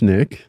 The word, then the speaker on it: Nick: So my name is Nick. Mm-hmm. That rhymes with Dick.